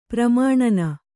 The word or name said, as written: ಪ್ರಮಾಣನ